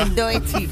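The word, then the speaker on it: Doechii: A ver, ¿esto cómo se pronuncia? – Doechi. – Muy bien.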